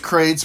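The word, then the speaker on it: Qrates: It's pronounced Crates, but it's Q-R-A-T-E-S.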